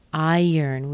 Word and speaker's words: iron: Iron. We connect it together with a little Y sound. Iron. If I were to spell this more phonetically, I would probably say I-Y-R-N. E-R-N, iron. So not iron, iron.